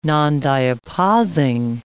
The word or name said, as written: nondiapausing